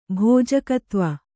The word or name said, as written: ಭೋಜಕತ್ವ